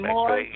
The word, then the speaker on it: McVeigh: Yes, and the last of all, anniversary, Joe and Tanya McVee. I think I'm saying it right. – McVeigh. – And McVeigh. You're doing a good job, but it's McVeigh. – Morris and Denise Turner. Happy anniversary. – Happy anniversary. – Walter and Ofa, I-O-P-H-I-A.